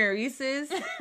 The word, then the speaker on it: Reese's: We say Reese's as a Black community, but it's Reese's.